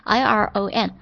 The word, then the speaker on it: iron: iron 它的发音呢英式的超简单，英式就是 iron, 但是美式的话呢它不是 iron 啊，它是 iron 就是 iron, 不是 iron, 是 iron, 顺带讲了一下这个单词，因为它太出众了，太不一般了。